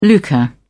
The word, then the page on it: lucre: British English